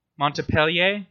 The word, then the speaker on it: Montpellier: So uh, some more um uh, influences that uh, Rabelais had um in the uh, University of Montpellier's Montpellier. Sorry, is that how you pronounce it? – Yeah, Montpellier. Yeah.